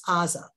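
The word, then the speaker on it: Aza (עזה): And just for those of you who don't get this, in English we call it Gaza. The Hebrew is Aza. There are the pronunciation of that ayin in many dialects is a gimel, but it's really Gaza, the same old place where we have lots of trouble from them.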